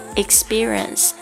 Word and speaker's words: experience: experience，特别注意一下它的发音，呃，这里面的 p 呢，我们并不把它爆发出来，为什么呢？因为它前面紧接的一个这个 s 的这个清音呢，